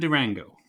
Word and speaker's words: Durango: Although she went by the name of Durango, a word which you'll find rhymes with mango.